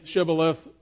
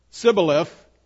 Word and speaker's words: Shibboleth: And he would say, "Shibboleth, Sibboleth," for he could not pronounce it right.